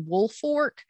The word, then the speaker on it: Woolfolk: And I will say this too, before I continue, I'm pronouncing it correctly in Woolfork, but it is spelled Woolfolk, like wool from sheep and folk, like people, but you pronounce it Woolfork.